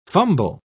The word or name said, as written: fumble